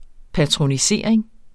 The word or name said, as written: patronisering